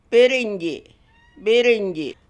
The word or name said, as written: Birrinyji